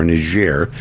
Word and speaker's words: Niger: By the way, the World Health Organization declares that Niger, or Niger, as they pronounce it, former French colony, that is the single worst place in the world to be a child.